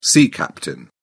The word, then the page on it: sea captain: British English